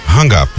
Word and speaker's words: Hang Up: Peraí que o DJ tá corrigindo minha pronúncia aqui. Como é que é? – Hang Up. – E eu falei o quê? – Rang It Up.